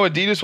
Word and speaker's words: Adidas: I know Adidas will be one because it's Adi Dassler,